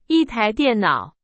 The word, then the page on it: 台: Chinese